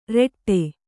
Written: ರೆಟ್ಟೆ

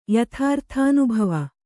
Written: ಯಥಾರ್ಥಾನುಭವ

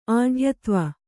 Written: ಆಢ್ಯತ್ವ

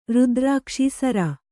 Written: ರುದ್ರಾಕ್ಷಿ ಸರ

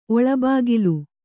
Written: ಒಳಬಾಗಿಲು

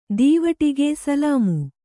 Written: ದೀವಟಿಗೆ ಸಲಾಮು

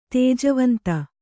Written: ತುಜಾವಂತು

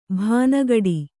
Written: ಭಾನಗಡಿ

